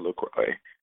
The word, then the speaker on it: LaCroix: And then I was like, wait, I should make sure I'm pronouncing it right. And I looked on their website and I was like, oh, it's actually LaCroix.